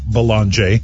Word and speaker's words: Belanger: And yes, we confirmed. He said, go ahead, pronounce the name Belanger.